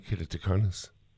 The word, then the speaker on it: keratoconus: I've not heard of the condition, and I hope I'm pronouncing it right, keratoconus. – I think you got it absolutely perfectly.